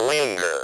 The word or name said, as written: linger